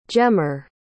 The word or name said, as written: gemmer